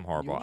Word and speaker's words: Harbaugh: Just more and more, I guess, fuel to the fire up there with Jim Harbaugh. Am I saying that right? Mm-hmm. Yeah, Jim Harbaugh.